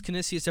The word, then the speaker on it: Canisius: It's pronounced Canisius, everybody. That was Locks of the Week here on this Friday crew.